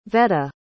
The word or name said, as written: veta